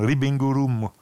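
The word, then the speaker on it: Ribbing Room (リビングルーム): Na, wie Living Room, nur eben auf Japanisch ausgesprochen. Ribbing Room.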